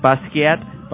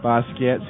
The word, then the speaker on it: Basquiat: Have you seen the American film Bisquite? – Basquiat? – Basquiat.